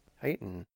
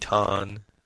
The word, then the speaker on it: Titan: He is talking about the planet Titan. Like, it's not a planet. – Teton.